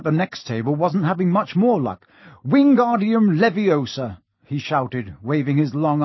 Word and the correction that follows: Wingardium Leviosa: "You're saying it wrong," Harry heard Hermione snap. "It's Wingardium Leviosa. Make the gar nice and long."